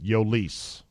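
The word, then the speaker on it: Jhoulys: And that's how you pronounce it, Yolise.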